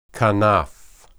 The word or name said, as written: kanaph